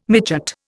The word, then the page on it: midgut: Indian